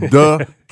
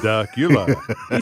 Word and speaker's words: Dacula: So it's not Dacula, huh? – It's not. It's Dacula. – Dacula.